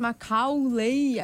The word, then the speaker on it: Macaulay: Uma falha de pronúncia do Capu. Porque se ele tivesse falado Macaulay, a gente teria falado.